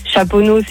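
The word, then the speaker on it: Chaponnay: Chaponneau, c'est ST, tu le prononces pas.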